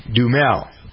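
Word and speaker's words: Duhamel: Dumel. – So there's a, you don't pronounce the A. It's D-U-H-M-E-L, kind of.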